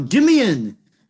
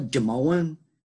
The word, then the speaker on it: Jimeoin: J-I-M-E-O-I-N. – Say it again. Gay. – Yeah, that J-I-M. – Yep. – E-O. – Oh, Jimian. You're fucking talking about Jimian, not Jamoan.